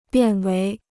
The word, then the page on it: 变为: biàn wéi